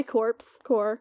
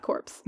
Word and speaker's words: corps: It's their corps. It's not corpse. – It's spelled that way, though.